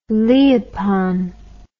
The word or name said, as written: leopon